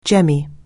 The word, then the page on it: jemmy: anglais